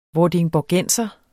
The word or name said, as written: vordingborgenser